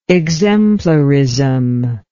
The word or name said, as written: exemplarism